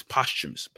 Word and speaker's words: posthumous: See, we've been trying to figure out how to say this word because it's spelled posthumous. – But Google said it's posthumous. I think it's posthumous. – Posthumous. So if you are watching and you know the correct way to say, please correct them. But we're gonna go with posthumous.